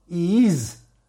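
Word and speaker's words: Yiz (ייז): Deberás meditar el santo nombre de Hashem Yud Zain, Yud Yud Zain, que se pronuncia Yiz,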